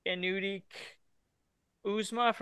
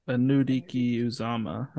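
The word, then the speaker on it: Anudike-Uzomah: Felix Anudik Uzma from Kansas State. I've definitely butchered that name, but that's what I'm going to take. – Felix Anudiki Uzama, I think. Uzama is definitely the end, but.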